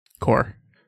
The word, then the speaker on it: Corps: This one just says Invasion of the Daemon Corps. – Ah, cool. Daemon Gundan no Ichi. Also, that's not how that word's pronounced. – Core.